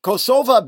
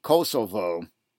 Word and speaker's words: Kosovo: Kosovo being the pronunciation which is used by the Albanian majority of that country, as opposed to the more common rendering, Kosovo, used by the Serb minority.